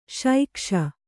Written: ಶೈಕ್ಷ

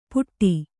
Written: ಪುಟ್ಟಿ

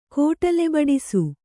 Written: ಕೋಟಲೆಬಡಿಸು

